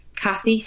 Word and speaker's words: Cathi: Cathi, C-A-T-H-I, Cathi Hargaden.